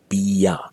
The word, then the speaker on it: bia: In Burmese pronunciation, usually people say bia. So instead of asking beer thao chin la, Burmese person might ask you bia thao chin la.